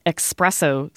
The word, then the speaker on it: espresso: We have the insertion of a k sound. The coffee is spelled E-S-P-R-E-S-S-O, espresso. But in English, we have many more words that start with E-X. And so it's not surprising that many speakers have started this word with X as well.